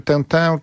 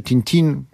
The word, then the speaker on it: Tintin: I właśnie to jest ciekawe, że oczywiście Tintin czy Tintin, możemy wymawiać tak i tak, nie jest takim typowym dorosłym, a Asterix i Obelix również trudno by ich nazwać standardowymi dorosłymi.